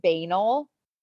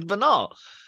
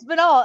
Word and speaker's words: banal: I pronounced it banal. – And I was like, "It's banal. – It's banal." And then like a bunch of listeners were like, "It is 1000%. Banal."